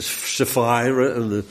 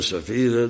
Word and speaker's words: Sapphira: Ik moet het eigenlijk in het Engels uitspreken, Amerikaans. Met die eyes, Ananias en Sapphira. Maar goed, Ananias en Safira, dat is dan wel erg moeilijk.